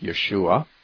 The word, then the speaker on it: Yeshua: It's true that in Hebrew, the name Jesus would be pronounced something more like Yeshua.